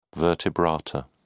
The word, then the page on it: vertebrata: British English